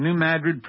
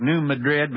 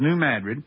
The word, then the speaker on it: New Madrid: He says the New Madrid quakes, and by the way, I'm pronouncing New Madrid properly for those of you who speak Spanish. It looks like New Madrid, but in Missouri, we talk funny. It's New Madrid.